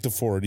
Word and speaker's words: Deford: Yeah, Frank DeFord.